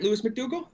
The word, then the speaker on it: Lewis McDougall: Did I say that right, Lewis McDougall? – Got that right.